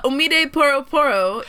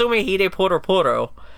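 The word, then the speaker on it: Omide Poro Poro (おもひでぽろぽろ): Omide Poro Poro is. – You pronounced that right.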